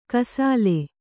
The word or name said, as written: ಕೈಸೋಲು